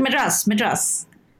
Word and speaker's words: Madras: University of Madras? Is that how you pronounce it? – Oh, yeah. University of Madras.